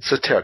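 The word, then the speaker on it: Soetoro: Because it was Soweto, or how do you pronounce that last name? – Sotaro? – Sotaro. Okay, that was his Obama's stepdad's name or something like that.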